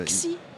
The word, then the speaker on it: Xi: Xi. – Ah, c'est mal. – Le X, ouais. I ouais. en chinois va se prononcer S.